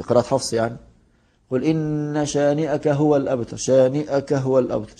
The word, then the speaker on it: شانئك: إن شانئك، في بعض الناس بينطقوا الكلمة دي غلط، يقولوا إن شانئك، طب ما فيش مد، أنت ليه بتمد؟ تقول إن شانئك، من النون للشين لا تفصل بينهما بمد، لا تقول إن شانئك، إن شانئك هو شانئك، طبعا دي فيها خطأ كتير من بعض الناس، يقول يعني قد لا ينطقها نطقا صحيحا، ولكن الصحيح فيها شانئك،